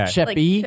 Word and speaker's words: Sheppy: Is it Sheppy or Sheffy? – I think it has Sheppy. A P. – Okay. – Sheppy. – Like, Sheppy. – Like okay. A shepherd.